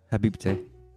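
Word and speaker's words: habibti: Habibte. Because that's how you say it in Lebanese.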